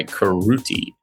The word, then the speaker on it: Karuti: K-A-R-U-T-I. Karuti. It is a waifu project. Anime ladies. – Yes. Karuti. Still doing that, I guess. – Rhymes with booty.